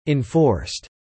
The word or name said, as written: enforced